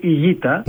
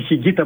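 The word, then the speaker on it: Higuita: Το λέω γιατί τα νεότερα παιδιά, εντάξει, μπορεί να μην τον ξέρουν, αλλά η ισπανική προφορά είναι η Γκίτα. – Ναι, αλλά άντε να πεις τώρα σε κάποιον από τους παλιούς, τον Χιγκίτα που τον λέγαμε εμείς μια ζωή, να τον πεις η Γκίτα.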